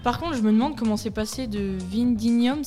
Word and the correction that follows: Vindinum: c'est ça? – Vindinum. – Oula, Vindinum, attention à la prononciation, à Le Mans.